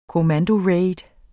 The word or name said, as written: COMMANDORAID